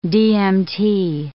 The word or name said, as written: DMT